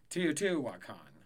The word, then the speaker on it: Teotihuacan: Okay. Teotihuacan is how you pronounce it.